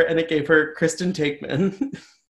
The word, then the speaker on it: Taekman: Well, she she pronounced her name wrong. She's like, who's Kristen like Tackman? Tackman.